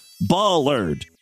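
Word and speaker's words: bollard: By the way, the correct pronunciation is ballard. Like a sheep, ballard. I didn't know that either.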